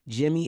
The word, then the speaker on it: Jimmee: You can find me everywhere at Call Me Jimmee. That's C-A-L-L-M-E. Jimmee is spelled J-I-M-M-E-E.